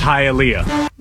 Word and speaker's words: Hialeah: It's pronounced Hialeah.